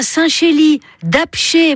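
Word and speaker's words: Saint-Chély-d'Apcher: Vous avez un Saint-Chély d'Apché, mais alors ça s'écrit avec un R, mais on ne prononce pas le R.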